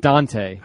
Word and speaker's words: Dunta: Is it Dunta? – I believe he pronounces it Dante. – Yeah, well, spell it Dante. Dunta. – Yeah, spell it D-A-N-T-E.